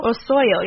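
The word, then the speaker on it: Osollo: Osoyo? – Osoyo, yeah. Mm-hmm. I would say there's 2 L's, so Osoyo is the creator of Shadow People, who, which is a book that we reviewed.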